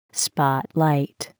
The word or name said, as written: spotlight